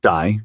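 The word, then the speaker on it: Dai: A lot of men were called Die, pronounced Die, short for David, or Dafydd in Welsh.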